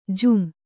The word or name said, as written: ಜುಮ್